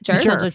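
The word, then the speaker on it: Djerf: How do you say? Is that Jerf? – Jerf. Yes.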